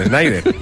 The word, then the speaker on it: Schneider: Eso te pasa por no confiar en tu pronunciación, que la habías dicho bien, Schneider.